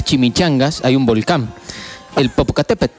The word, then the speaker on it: Popocatépetl: El final se pronuncia de una forma más acentuada. A ver cómo sería. – Popocatépetl.